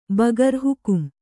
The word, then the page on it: ಬಗರ್ ಹುಕುಂ: Kannada